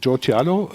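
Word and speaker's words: Joe Chialo: Letztendlich, vergangene Woche hattest du den Joe Tialo, habe ich ihn richtig ausgesprochen? – Ja, absolut korrekt.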